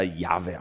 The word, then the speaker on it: Jahwe: Jahrhundert gab es dann ein paar ganz schlaue Bibelforscher, die haben angefangen zu rekonstruieren, wie könnte denn der ausgesprochen worden sein. Und so die ersten Phase in dieser Erforschung, da wurde das dann Jehova genannt. Wahrscheinlich wird er Jehova ausgesprochen, und ein paar religiöse Gruppierungen sind da hängen geblieben im 18. Jahrhundert. Aber in der Weiterentwicklung ist man dann drauf gekommen, wahrscheinlich hat es sich eher Jahwe ausgesprochen oder Jahwe im Hebräischen.